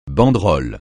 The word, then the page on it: banderole: francais